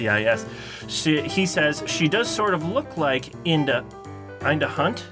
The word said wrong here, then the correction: Linda Hunt: Is that how you say her name? I don't know. From NCIS LA, but really... – I think it's Linda. – Oh yeah, Linda. It is Linda. – Linda Hunt.